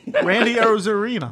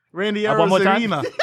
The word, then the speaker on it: Randy Arozarena: Randy Arozarena. – Yeah, say it again. – Randy Arozarena.